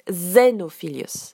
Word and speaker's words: Xenophilius: En français, il s'appelle Xenophilius et en, anglais, on prononce Xenophilius. Donc Xenophilius devient Xenophilius.